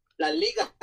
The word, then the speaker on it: La Liga: La Liga? – La Liga.